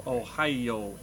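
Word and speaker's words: Ohio (おはよう): But it has one more syllable because Japanese syllabize everything. It's Ohio in Japanese.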